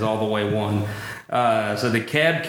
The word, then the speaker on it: DeKalb: Commissioner, how do you actually say it right? – We pronounce it DeKalb.